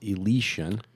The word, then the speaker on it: Elysian: The beer from Seattle is pronounced Elysian.